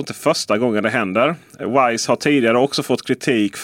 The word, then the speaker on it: Wyze: För användare av kameror från Wise, som jag antar att det uttalas som. Det stavas alltså W-Y-Z-E. Wise. Just det, eller vyse mm. kanske man ska säga på svenska då.